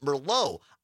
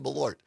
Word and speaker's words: Malort: So Malort and somebody, Julie or Jules in Mosinee, listening on WXCO, says, are you sure it's not pronounced Merlot? I actually looked it up, Jules, because I didn't know, and that's why I found that video of this guy reviewing it. And no, it's spelled differently than Merlot. It's M-A-L-O-R-T, and it's pronounced Malort.